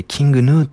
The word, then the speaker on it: King Gnu: あれ、あの人たちはキング、グヌって書いてキングヌーって読むんですね。